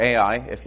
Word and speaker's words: Ai: And when they went to go take the next city, Chai or Ai, the way it's usually pronounced in English, they lost.